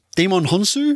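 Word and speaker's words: Djimon Hounsou: Shazam, starring Zachary Levi, Mark Strong, Demon Hunsu. Is that how you say his name? – So it's close enough.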